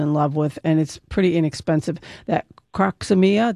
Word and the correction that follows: Crocosmia: did I say that right? – Crocosmia.